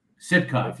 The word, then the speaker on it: Sitcov: Sitcov, yes.